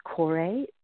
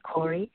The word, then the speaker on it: Kore: And some people pronounce this Kore, but I went with the Greek pronunciation, Kori.